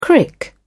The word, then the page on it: crick: British English